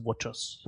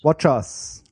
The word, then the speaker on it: watchOS: Und das Interessanteste eigentlich daran ist, es gibt jetzt auch das WatchOS für NativeOS, also natives SDK für das zweite WatchOS. Und da ist das Pflicht. Also bei allen anderen Korrektur, Apps. – Die richtige Aussprache ist WatchOS. – WatchOS.